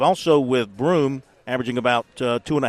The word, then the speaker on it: Broome: I said Brown. His name is Broome, Johni Broome, 6'10 center forward out of Plant City, Florida.